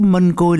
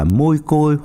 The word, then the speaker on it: mân côi: Quý thính giả chắc cũng biết chứ, mân côi là cách phát âm từ tiếng Hán Việt nghĩa là hoa hồng, rosa hoặc rosary. Có nhiều nơi đọc là môi côi hoặc mai côi hoặc mai khôi.